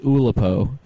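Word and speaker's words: Oulipo: And, I don't know, it's named after a conceptual idea where you put, like, constraint games on writing, which is actually pronounced Olipo. I heard it wrong.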